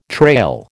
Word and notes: trail: American English

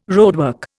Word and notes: roadwork: Indian

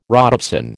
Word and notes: rhodopsin: American English

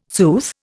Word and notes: sooth: Indian

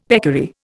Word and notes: peccary: Indian

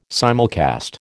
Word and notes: simulcast: American English

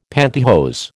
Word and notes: pantihose: American English

